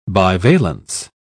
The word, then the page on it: bivalence: British English